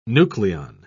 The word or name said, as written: nucleon